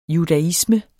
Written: judaisme

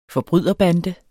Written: forbryderbande

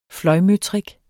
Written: fløjmøtrik

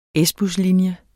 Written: S-buslinje

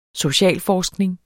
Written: socialforskning

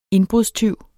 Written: indbrudstyv

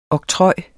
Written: oktroj

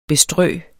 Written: bestrø

